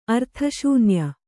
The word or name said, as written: ಅರ್ಥಶೂನ್ಯ